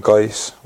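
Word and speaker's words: GAIS: I think there was some contact like over here obviously had a contact over in Guy's, was the club I was in Gothenburg. – I'm glad you said that, because we had no idea how to pronounce it. – To be fair, I said Guy's.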